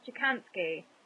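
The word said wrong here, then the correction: Kochanski: Chrissy Kochansky.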